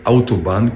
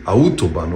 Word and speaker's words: Autobahn: Καταρχάς, να το προφέρουμε σωστά. Λέγεται Autobahn και όχι Autobahn, όπως το προφέρουμε.